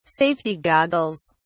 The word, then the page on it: safety goggles: British English